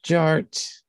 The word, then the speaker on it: DJ Art: The D is silent, so it's just Jart.